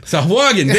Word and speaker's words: Sahuagin: Sahuagin, thank you.